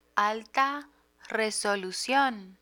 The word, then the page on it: alta resolucion: spanish